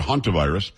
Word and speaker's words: hantavirus: Young, very smart guy, talking to us about hantavirus. He actually pronounced it huntavirus.